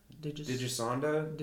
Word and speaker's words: Digisonde: It has so the VHF, it has the instruments such as the VHF, the UHF radar, a fluxgate magnetometer, a digisit. All right, somebody else pronounce this for me. Digisonde. – Digisonde. – Digisonde? An ionosphere sounding device is what it is.